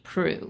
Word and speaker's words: Proulx: The name with the author, Annie Prue, spelled P-R-O-U-L-X, but pronounced Prue.